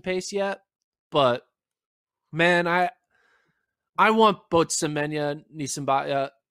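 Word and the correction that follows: Niyonsaba: Nee and Saba.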